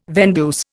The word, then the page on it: vendeuse: Indian